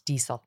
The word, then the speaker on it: Diesel: I always say diesel, but it's diesel. I've learned that in listening to you with a soft S.